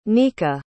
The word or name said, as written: nica